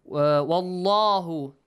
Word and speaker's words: Wallahu: Wallahu, the word Allah, the lam in the word Allah must be pronounced. – Heavy. – With a heavy sound. Wallahu. Heavily. Yeah? Full mouth. Wallahu. Bima ta'maluna khabir.